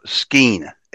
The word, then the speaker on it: skene: Pronounced skein, S-K-E-N-E is skein.